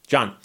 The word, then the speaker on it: John: And I appreciate the note there because, of course, John is pronounced both ways, with an H, without an H, the same way. John.